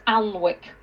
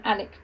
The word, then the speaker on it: Alnwick: But if you're not local, you'll think it's Alnwick. It's spelt A-L-N-W-I-C-K, but okay, you call it, you say Alnwick.